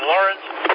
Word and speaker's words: Laurens: Yeah, it's spelled L-A-U-R-E-N-S, Lawrence. Not Lawrence.